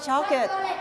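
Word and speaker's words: chocolate: ช็อกเกิลช็อกเกิล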